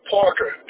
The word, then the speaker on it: Parker: Parker. P-A-R-K-E-R.